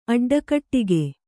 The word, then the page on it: ಅಡ್ಡಕಟ್ಟಿಗೆ: kannada